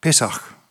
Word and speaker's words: pesach: Och postur, ta hebraisk ordet till er pekesh, ursäkta, pesach, det uttalas 100%, men cirka det.